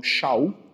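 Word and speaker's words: Shaul (שאול): Na verdade o nome dele era Saul, né? – Ou Shaul, né? Como é pronunciado lá no, no aramaico, no hebraico, né?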